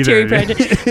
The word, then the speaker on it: Terry Pratchett: Have you read any Terry Pratchett? Am I saying the name right? – Yeah, Terry Pratchett.